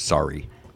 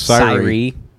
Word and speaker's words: Syria: There's a town in Lawrence County called spelled Syria. Mm-hmm. And at least for my dad's generation, they pronounce it sorry. – Pickens' church is Syrie.